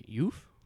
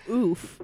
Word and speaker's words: Youf: It is Cosmic Youf. – There you go. – Youf. – Like euphoria. – Yes, like euphoria, because I couldn't pronounce it for the longest time. – He kept saying oof.